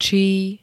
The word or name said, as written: čí